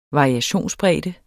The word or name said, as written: variationsbredde